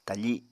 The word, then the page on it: tafi: Apatani